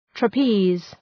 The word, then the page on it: trapeze: trə’pi:z